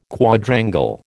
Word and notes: Quadrangle: American English

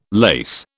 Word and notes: lase: British English

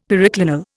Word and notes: periclinal: Indian

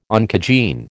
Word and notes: oncogene: American English

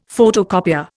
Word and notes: photocopier: Indian

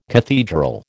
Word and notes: cathedral: American English

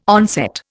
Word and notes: onset: Indian

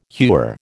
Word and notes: hewer: American English

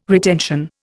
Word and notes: retention: Indian

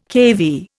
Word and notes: keV: Indian

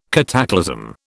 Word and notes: cataclasm: British English

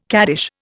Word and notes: caddish: Indian

